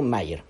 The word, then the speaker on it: Meyer: Pero, pero, pero a esto se suma que se inventó un personaje llamado Mayer. Meyer se escribe.